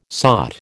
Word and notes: sought: American English

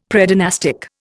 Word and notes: Predynastic: Indian

